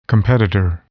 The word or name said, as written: competitor